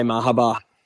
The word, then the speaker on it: Mahaba: I'll probably just say Mahaba because I really liked its run down the Flemington Straight.